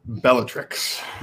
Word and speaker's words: Bellatrix: And then Beatrix Lestrange is the person who kills Dobby. 14-3 at this stage. Yes? – I believe it's pronounced Bellatrix.